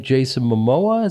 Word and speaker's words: Jason Momoa: But I remember you saying Jason Momoa.